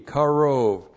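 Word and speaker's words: Karov (קרוב): Looks like that in the Hebrew, Q A R long O B, Karov, and Karov means near.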